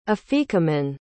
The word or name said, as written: afikomen